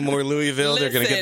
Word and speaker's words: Louisville: But, so pronounce it right. It's Louisville.